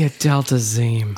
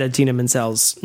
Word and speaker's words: Idina Menzel: The Adele Dazeem. – I didn't until you just said that. – Never forget. Mostly, wow. What I love is everyone remembers that he, of course, said Idina Menzel's name wrong.